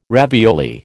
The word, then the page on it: ravioli: American English